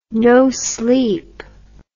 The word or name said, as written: no sleep